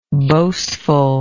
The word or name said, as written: boastfulness